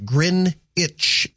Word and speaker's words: Greenwich: FYI, Greenwich Mean Time is pronounced grin itch, as in green, as in grin itch meantime.